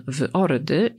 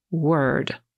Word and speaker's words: word: Nie mylić oczywiście ze słowem, które znaczy słowo, zapisywanym w ord i wymawiamy je word. Tak powie Amerykanin, a Brytyjczyk word.